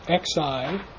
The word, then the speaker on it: Xi: In our case here, tongjiazi happened with Xi, which is the character over to the right, spelled in pinyin, XI, not QI, and pronounced Xi, phonetically SHEE, and it means a grain ration, or to give rice, or to give nourishment.